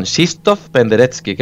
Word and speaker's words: Krzysztof Penderecki: No, perdón, Krzysztof Penderecki, que es como se pronuncia, ¿vale? Porque es que se escribe Krzysztof Penderecki, pero no, es Krzysztof Penderecki.